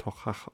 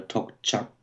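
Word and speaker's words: Toiteach: Horst Düding behauptet, er wird Tochach ausgesprochen. – Ja, ich habe auch schon mal Tokchak gehört. Mhm. Würde man es Englisch aussprechen, was draufsteht, würde man Toy Teach sagen ja, wahrscheinlich begegnet mal jeder Aussprache öfter mal.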